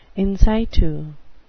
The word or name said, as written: in situ